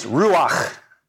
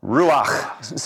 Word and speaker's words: ruach: But it's ruach. Ruach. Say that with me. Ruach. You've got to get a little of that phlegm there at the end to get that last part right.